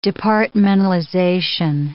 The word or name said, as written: departmentalization